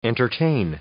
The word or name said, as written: entertain